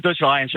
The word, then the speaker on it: Dutch Lions: Dat is de Dutch Lions AVFC. Zeg ik het goed zo? – De Dutch Lions, ja, ze weten die. – Ja. Ik mag gewoon de Dutch Lions zeggen, ja.